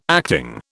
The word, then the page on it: acting: British English